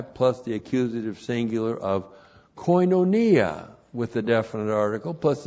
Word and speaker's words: koinonia: And then we have koinonia. Koinonia. Looks like that in the Greek. K-O-I-N. Long O-N-I-A. Koinonia.